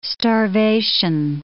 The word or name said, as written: starvation